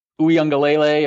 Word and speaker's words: Uiagalelei: And then, like you said, DJ Uiagalelei, if I'm pronouncing that right.